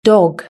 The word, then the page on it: dog: british-english